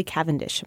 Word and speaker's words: Cavendish: So on Snatch, you play uh, Charlie Cavendish. Am I saying that correctly? – Yeah.